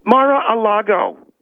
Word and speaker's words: Mar-a-Lago: Because there was a picture that was posted today of her during that period of time, during the visit to Mar-a-Lago, or however you pronounce it. – Talk much? – I'm going to come through this phone. – Mar-a-Lago is the way it's pronounced.